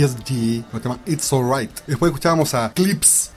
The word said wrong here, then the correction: Clipse: O si no, Clipse. Se escribe Clipse. – Como Eclipse.